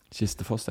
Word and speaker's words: Kistefoss: Jeg vet ikke hvordan det uttales, om det Kistefoss eller Kistefoss. – Kistefoss, ja.